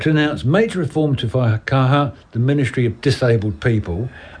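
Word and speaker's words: Whaikaha: Is that how you pronounce it? Faikaha? – Yeah. – Faikaha.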